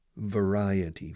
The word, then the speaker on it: variety: Variety. Fijaos, ¿cómo se pronuncia variedad en inglés? Variety. Variety.